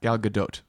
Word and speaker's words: Gal Gadot: Gal Gadot. and she pronounces the T.